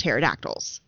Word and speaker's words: pterodactyls: I was obsessed with pterodactyls. – Aww. – Because the P is silent.